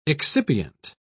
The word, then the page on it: excipient: British English